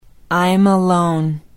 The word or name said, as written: imalone